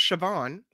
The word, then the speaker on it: Siobhan: This is pronounced Siobhan, right? – Yes.